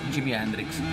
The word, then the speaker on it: Jimi Hendrix: Jimi Hendrix. Corretto? Ho sbagliato? – No, no, no, detto bene, un attimo fatto.